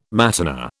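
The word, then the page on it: matinée: British English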